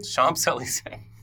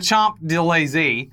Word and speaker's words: Champs-Élysées: Now, on Bastille Day, the Champs-Élysées, did I say that right? – Champs-Élysées. The big street. – The Champs-Élysées.